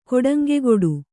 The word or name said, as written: ಕೊಡಂಗೆಗೊಡು